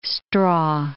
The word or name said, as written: straw